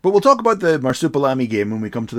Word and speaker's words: Marsupilami: so marsupial army. – How's that? That's not how you say it.